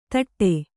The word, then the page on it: ಧಟ: dhaṭa